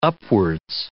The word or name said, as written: upwards